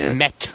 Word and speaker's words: Meta: It's actually Meta. – It's a militia. – It's Meta Militia. Not with an L.